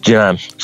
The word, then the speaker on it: Jinan: Is it just, I don't know how you pronounce this, Jinan, or will other provinces follow this in China, of course? Well, how do you pronounce that? – Jinan.